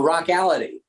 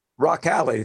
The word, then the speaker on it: Rockality: I'd like you to tell us a bit about this, if I'm pronouncing this right, the Rockality video series. – Oh, Rockality. – Rockality, thank you. – Yeah, yes, like, tell me about the Rockality video. – Yeah, it's like reality, but it's Rockality.